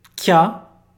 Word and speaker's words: kya: Kya é o ki, mais ya pequeno vira kya. Ele tem uma sílaba, tudo bem? É diferente de kya, que são duas sílabas. Kya só tem uma só, ok?